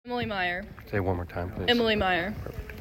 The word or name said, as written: Emily Meyer